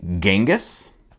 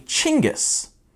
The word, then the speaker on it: Chinggis: How do you pronounce that name? Is it Genghis? Is it Genghis? No, you'd be wrong. It's actually Chinggis.